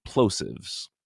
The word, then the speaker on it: Plosives: I've been trying to figure out how to pronounce this band's name, and then I realized it's Explosives, but just like shortened to Plosives.